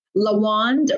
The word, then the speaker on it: Lawand: And if you have trouble remembering the spelling of my name, Lawand rhymes with the wand.